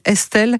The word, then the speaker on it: Estl: Elle s'appelle Estelle, mais ça s'écrit E-S-T-L, découverte par les réseaux sociaux, également par The Voice, mais la version belge.